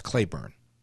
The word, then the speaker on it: Cleburne: It's spelled C-L-E-B-U-R-N-E, but it's pronounced Claiborne.